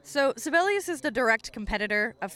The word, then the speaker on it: Sibelius: Another program that is similar to Finale, but offers a slightly different aesthetic and final important package, is Sibelius or Sibelius. You'll hear different pronunciations. That is Sibelius.